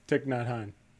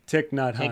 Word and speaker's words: Thich Nhat Hanh: Thich Nhat Hanh. – How do you say it? – Thich Nhat Hanh.